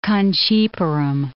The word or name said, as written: Kanchipuram